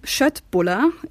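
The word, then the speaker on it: Köttbullar: Möbel und Schöttbuller, ja, das wird wirklich so ausgesprochen, gibt es künftig also auch in der Fußgängerzone.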